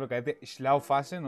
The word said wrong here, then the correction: Schaffhausen: Mi, Schaffhausen.